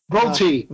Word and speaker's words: VoLTE: Is that pronounced VoLTE or VoLTE? – VoLTE.